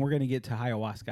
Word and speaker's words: ayahuasca: Ayahuasca. – But ayahuasca. – White people want it sound. – It's A-Y-A-H. Ayahuasca. – Oh. – Yeah. – Well, okay. – And you don't pronounce the H.